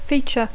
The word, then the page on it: feature: English